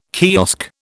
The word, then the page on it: kiosk: British English